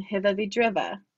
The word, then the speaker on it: Hiwwe wie Driwwe: publication Hiva Vidriva. Did I say that right? – That's correct.